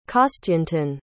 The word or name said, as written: kostyantyn